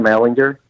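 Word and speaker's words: Ehlinger: to Sam Ellinger? They're all shitting their pants. – It's a hard G, by the way.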